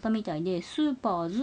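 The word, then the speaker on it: セーラームーンS: S って書くんですけどこれがスーパーって読みます。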